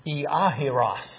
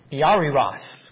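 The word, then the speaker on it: Iahiros (Ἰάϊρος): Iahiros is how his name is pronounced in Greek. Now, you can allegorize that one. Iahiros, Jairus.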